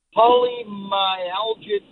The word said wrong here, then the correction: polymyalgia rheumatica: Okay, polymyalgia rheumatica.